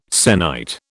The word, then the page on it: sennight: British English